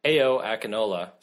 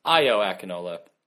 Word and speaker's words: Ayo Akinola: The last goal was scored by Ayo Akinola. Sorry, Ayo Akinola. – I've been mispronouncing it. It's Ayo Akinola, which made me realize that we could someday see an Ayo Waya.